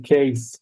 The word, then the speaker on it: Kees: Case, but in Dutch. In Dutch, it's K-E-E-S.